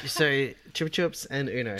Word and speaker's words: Chupa Chups: It's apparently it's got its own website called Chupa Chup World. – Or Chupa Chup World. – Whatever. – It is Chupa Chup. – It's pronounced Chupa Chups. – Yeah! – And Chupa Chups in the UK. There are plenty of other pronunciations around the world.